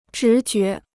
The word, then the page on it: 直觉: zhí jué